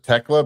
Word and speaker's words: Thekla: I think they pronounce it Tekla, but it's Thekla.